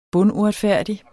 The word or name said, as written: bunduretfærdig